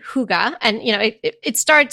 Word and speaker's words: hygge: Have any of you guys heard of that hygge, which is spelled H-Y-G-G-E? So for the longest time, I thought it was like huggy, which I think is kind of better than hygge.